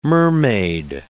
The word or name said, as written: mermaid